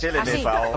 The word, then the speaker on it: LMFAO: De LMFAO. ¿Cómo se dice esto? – Pues LMFAO.